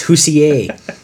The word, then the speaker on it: Hoosier: You're a Hoosier? – I'm a Hoosier. – Mm-hmm. Hoosier? – Hoosier. – It's Hoosier. It's like Dossier.